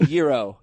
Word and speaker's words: Yairo: Y-A-I-R-O. Yairo, I guess? Munoz. – It's pronounced Yiro. Yiro.